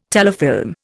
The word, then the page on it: telefilm: Indian